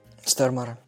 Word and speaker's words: Стормаре: Но все исправилось в конце, когда появляется харизматичнейший Люцифер, сыгранный Петером э, Стормаре, по-моему, так он читается, или Стормаре, не знаю. – Да, Стормара.